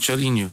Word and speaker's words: chorinho: Chorinho.